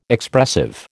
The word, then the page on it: Expressive: American English